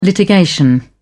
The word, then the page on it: litigation: British English